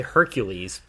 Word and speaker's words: Hercule: It's basically Hercules, but Hercule.